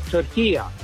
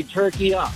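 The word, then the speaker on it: Türkiye: As far as the pronunciation of turkey, it's very easy. At the end is a short A. It's not a long A. In the Arabic language, it's called turkiya. So you could say turkiya.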